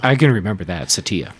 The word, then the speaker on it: Satya: And it's why I think that Satya, by the way, his name, it turns out, is pronounced Satya.